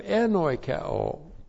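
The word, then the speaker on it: En oikeo (ἐνοικέω): Well, first of all, we have enoikeo. En oikeo. E-N-O-I-K-E long O. En oikeo.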